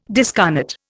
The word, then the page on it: discarnate: Indian